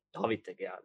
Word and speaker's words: David De Gea: No, David De Gea, let's pronounce it correctly.